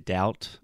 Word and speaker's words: doubt: So, when you said if I have a doubt or something like that, so the word doubt, first of all, the B is silent. Uh, So, we don't say that.